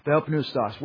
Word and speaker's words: theopneustos: Today's Greek scholars say that they would probably pronounce the P, so I tend to. Theo pneustos.